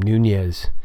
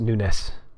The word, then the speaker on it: Nunes: Nunez. Who likes it pronounced Nunes. Nunes. My name is Nunes, not Nunez. Not Nunez. Nunes.